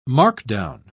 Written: markdown

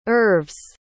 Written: oeuvres

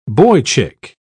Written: boychick

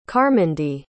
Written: carmindy

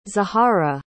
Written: zahara

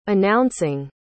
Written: announcing

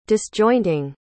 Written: disjointing

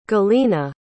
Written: galina

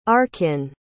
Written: arkin

